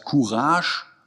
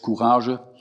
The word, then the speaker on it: Courage: Eigentlich, wenn man es korrekt aussprechen würde, wird das E eigentlich gar nicht mitgesprochen. Das heißt Courage, äh, aber eingedeutscht Courage.